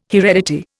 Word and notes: heredity: Indian

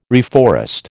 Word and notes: reforest: American English

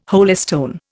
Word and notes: holystone: Indian

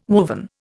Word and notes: woven: Indian

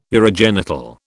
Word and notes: urogenital: British English

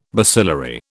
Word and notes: bacillary: British English

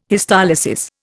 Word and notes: histolysis: Indian

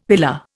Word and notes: pillar: Indian

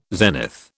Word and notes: zenith: British English

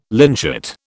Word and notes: lynchet: British English

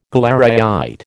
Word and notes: galleryite: American English

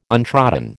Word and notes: untrodden: American English

